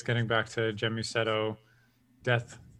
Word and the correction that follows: Gemusetto: First of all, it's a hard G. Okay. It's Gemusetto. – Gemusetto.